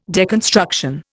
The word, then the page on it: deconstruction: Indian